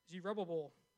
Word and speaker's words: Zerubbabel: they approached Zerubbabel." That's right. That's how you say it. I checked it out on YouTube. I practiced it 4 times.